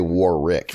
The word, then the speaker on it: Warwick: I read today that it's pronounced Warwick. The second W is silent, and I never do that.